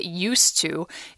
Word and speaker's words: used to: So when you say get used to, it's with a T, used to. And then when you say be used to, you separate used and to, be used to do this.